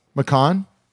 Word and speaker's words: McCaughan: I'll tell you how to say it. – M C C A U G H A N. – McCahan. Yeah.